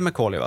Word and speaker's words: Macaulay: Macaulay Culkin, vet han som spelar i alltid undrar att man uttalar hans namn, men det är Macaulay va?